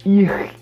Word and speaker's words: ich: que eles falam ir, um negócio assim, né? Ir, e que se escreve ICH.